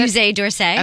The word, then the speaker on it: Musée d'Orsay: Cocktail dresses at Musée d'Orsay. I don't know how to pronounce it. – Musée d'Orsay.